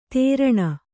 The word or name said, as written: ತೇರಣ